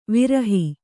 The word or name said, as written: ವಿರಹ